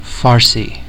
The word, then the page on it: Farsi: American English; [ˈfɑɹ.si]